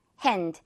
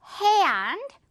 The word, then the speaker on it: hand: 이것도 발음 여러분, hand. 이렇게 당기지 않은 고무줄 발음 하지 말고 hand, 고무줄 당깁니다.